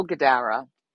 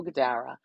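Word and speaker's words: Guidara: And it's by a gentleman named Will Gadara. I think I'm pronouncing his name correctly. It's G-U-I-D-A-R-A. Will Gadara.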